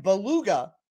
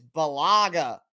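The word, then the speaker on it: Bulaga: It's not Brian Beluga like the whale. It's Balaga, I believe that is the correct way to say it.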